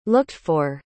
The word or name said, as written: looked-for